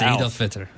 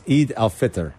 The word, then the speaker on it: Eid al-Fitr: It's Eid al-Fitr. – It's Eid al-Fitr. – Yeah. – Eid al-Fitr. I was close. Tomorrow. I just didn't know how to pronounce it.